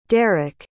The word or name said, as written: daric